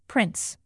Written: prince

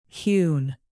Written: hewn

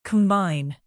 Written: combine

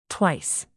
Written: twice

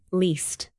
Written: least

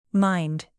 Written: mind

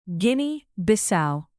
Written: Guinea-Bissau

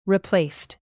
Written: replaced

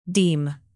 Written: deem

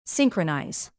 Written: synchronize

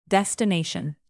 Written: destination

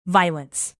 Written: violence

